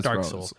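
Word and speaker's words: Dark Sol: And it turns out, like, the main bad guy is I assume it's pronounced Dark Soul. It's Dark S-O-L. – Yeah, it's Dark Soul.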